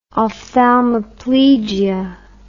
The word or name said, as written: ophthalmoplegia